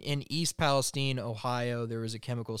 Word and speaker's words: East Palestine: Tragically, there was a chemical spill in East Palestine. Is that how it's pronounced? – I believe so. – East Palestine.